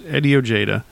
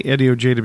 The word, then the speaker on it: Ojeda: Eddie Ojeda. And I say Eddie Ojeda because that's the way D. Snyder pronounces it, but it really should be Eddie Ojeda.